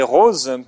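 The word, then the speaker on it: Roz: Donc c'est Rose qui nous écrit. Ça s'écrit R-O-Z, c'est joli, Rose, comme prénom et de façon d'écrire votre prénom.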